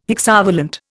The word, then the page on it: hexavalent: Indian